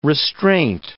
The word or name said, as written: restraint